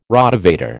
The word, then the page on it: Rotavator: American English